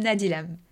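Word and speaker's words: Nadylam: It's William Nadilem. – So it's as simple as it looks.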